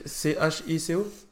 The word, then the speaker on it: Cheeko: je pense Chico ça s'écrit C H I C O euh, non C H E E K O Ok, ah d'accord, Chico, ok d'accord.